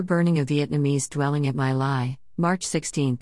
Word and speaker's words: My Lai: The My Lai massacre, pronounced My Lai, is considered the nadir in modern Army history.